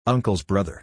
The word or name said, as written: uncle's brother